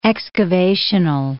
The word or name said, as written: excavational